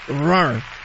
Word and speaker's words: Roar: Conéctate con Roar. Está a cargo de Katy Perry. Sí se dice así, ¿no? – Roar. – O Roar, Roar. Como Roar. Así. Roar. Así con la... Ajá, con el paladar. Roar.